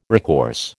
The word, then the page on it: recourse: American English